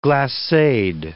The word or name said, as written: glaceed